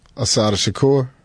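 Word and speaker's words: Assata Shakur: from Cuba, the first thing I thought about was Sister Shakira. Is that how you pronounce her name? – Asada Shakur.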